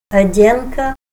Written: ad'enka

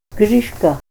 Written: Grishka

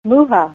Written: muha

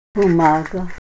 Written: bumaga

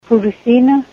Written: purusina

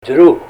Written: drux

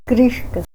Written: Grishka

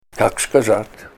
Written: Kak skazat

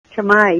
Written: chamai

